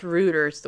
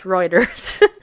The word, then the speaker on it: Reuters: which really should be pronounced Reuters the way it's sound there, but anyway, it's Reuters,